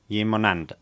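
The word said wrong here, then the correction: llimonada: You always put an N in there. Yimunanda. Yimunada. – Yimunanda. – Yimunada, yeah.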